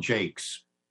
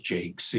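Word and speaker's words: Jaques: Well, then the best one is definitely from As You Like It, there's a character called Jakes. It looks like Jacques, but it's pronounced Jakes in OP,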